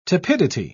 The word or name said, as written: tepidity